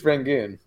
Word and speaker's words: Rangoon: I think it's Rangoon. – Rangoon. Why can't I say it right? I feel like I'm saying it weird. I feel like the A is a little sharper than you're making it.